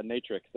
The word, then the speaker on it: Natrx: Yeah, Natrx.